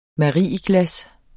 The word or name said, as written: MARIEGLAS